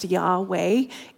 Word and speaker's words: Yahweh: And the true pronunciation of the name has been a mystery for centuries. So let that sink in. Though more recent scholarship does suggest Yahweh is correct.